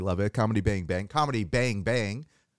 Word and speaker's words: Comedy Bang! Bang!: Yes, you're saying it correctly. Thank you so much for stressing the bangs. Most people just say comedy, bang, bang, and then they mumble the bang, bang.